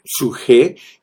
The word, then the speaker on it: psuje: El suje, que se escribe P-S-U-J-E, suje, que es eh, la parte psicológica del hombre y es la palabra vida para hablar de la vida del alma.